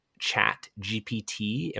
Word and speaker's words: ChatGPT: We have not had a submission this week, and so I tried for the very first time on this podcast to use one resource titled ChatGPT. Am I saying that correctly, Ethan? – That's correct.